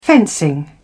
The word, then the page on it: fencing: British English